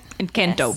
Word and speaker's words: Encanto: Encanto.